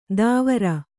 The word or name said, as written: ದಾವರ